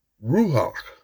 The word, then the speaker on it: Ruach: So after I dove into the Hebrew of this chapter, the Hebrew translation, I noticed the word Ruach, the C is silent, R-U-A-C-H.